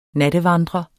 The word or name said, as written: nattevandrer